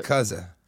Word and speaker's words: yakuza: And I don't want to be rude, but you're pronouncing it wrong. – What is it? – Yakuza. – Your cousin? – It's your cousin.